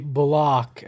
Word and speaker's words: Bullock: Look, Reggie Bullock, we've been told that's how we're supposed to pronounce it.